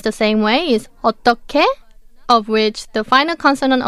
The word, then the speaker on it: 어떻게: The first one is 어떻게, which means how. The final consonant of the second syllable is 히읗, 어떻, and the last syllable is 게, 어떻게, but it's pronounced as 어떻게. Because when 히읗 meets 기억, it's combined and pronounced as 기억, so 어떻게.